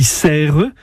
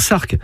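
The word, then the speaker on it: Sercq: J'ajoute que la forme anglaise du nom de l'île s'écrit avec un A. Mais ce n'est pas pour cela qu'il faut dire Serques. Ce serait ignorer la prononciation locale et la prononciation française.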